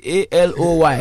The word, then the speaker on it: Aloy: Anyway, I see A L O Y.